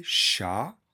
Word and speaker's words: chats: On écrit M-O-N-T-R-E-S. Le chat, chat, s'écrit C-H-A-T. Les chats, on écrit C-H-A-T-S. Un problème difficile, des problèmes difficiles. On n'entend pas le S à la fin.